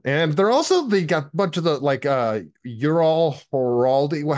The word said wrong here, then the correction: Harari: Noah Harari.